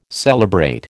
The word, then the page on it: celebrate: American English